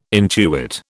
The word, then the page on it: intuit: British English